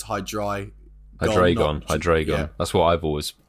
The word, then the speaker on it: Hydreigon: Hydreigon.